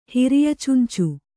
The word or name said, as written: ಹಿರಿಯಚುಂಚು